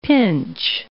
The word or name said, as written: pinch